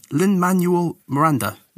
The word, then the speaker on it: Lin-Manuel Miranda: Lin-Manuel Miranda.